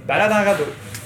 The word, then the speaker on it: Balade en Radeau: How do you say it properly? – Balade en Radeau. Balade, yeah, you got it pretty good.